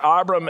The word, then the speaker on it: Abram: That's how it's pronounced in the Hebrew, in the Jewish culture, Abram and Sarai.